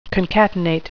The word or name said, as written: concatenate